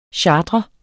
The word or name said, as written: CHARTREDES